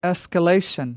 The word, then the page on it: escalation: British English